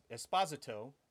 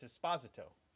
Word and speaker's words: Esposito: Giancarlo Esposito, which is actually how you pronounce it. That's how you pronounce his name. Yeah, it's Esposito.